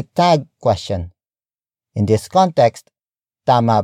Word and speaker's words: tama: Tama. Tama. Tama is spelled as T-A-M-A.